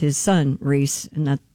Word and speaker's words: Rhys: Oh, it is Rhys.